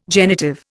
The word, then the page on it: genitive: Indian